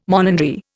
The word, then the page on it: monandry: Indian